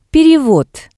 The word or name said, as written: perevod